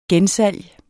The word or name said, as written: gensalg